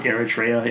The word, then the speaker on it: Eritrea: and I don't know how they pronounce it, Entria or whatever. – Eritrea in Africa.